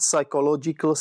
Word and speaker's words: psychological: So if you have P-S-Y in spelling, that's pronounced as psy in English. Psy, psychologist, psychological, psychiatrist.